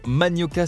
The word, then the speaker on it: Manioka: Manioca, ça s'écrit M-A-N-I-O-K-A, tiré du bas, cassave, c'est leur Instagram.